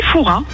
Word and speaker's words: Fouras: Eh bien écoutez, on dit Foura. – Ah ben, on ne prononce pas le S.